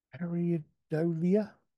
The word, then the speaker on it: Pareidolia: Pareidolia.